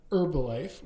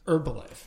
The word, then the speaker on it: Herbalife: It is Tuesday, March 8th, and today we're going to talk a bit about a merger arbitrage opportunity, Pfizer-Allergan, Herbalife. We've been debating whether we pronounce the H or not. I'm anti-pronouncing the H.